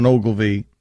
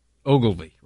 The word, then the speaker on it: Ogilvy: But it makes an appearance there where he puts the L before the I on, I think, the pronunciation on Ogilvy. – Ogilvy, right. – Am I wrong about this? – Not Ogilvy. – I have no idea. – Yeah, you put an extra syllable in there, buddy.